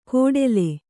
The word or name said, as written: ಕೋಡೆಲೆ